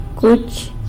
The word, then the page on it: cuch: sanlucasquiavini